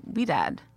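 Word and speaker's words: Ouidad: I got my first facial from, is it pronounced Weedad? – How do you spell it? – O-U-I-D-A-D. I never knew how to pronounce this. – Oh, Weedad. I think so.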